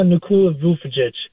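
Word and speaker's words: Nikola Vučević: Guys that I'm thinking about that Brad Stevens should be really aggressive on getting, Harrison Barnes, Miles Turner, Kyle Kuzma, Nikola Vucevic from Chicago. I probably butchered his name. I'm going to hang up. – You nailed it.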